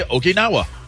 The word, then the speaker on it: Okinawa: I mean, how are you supposed to say Okinawa? – Okinawa.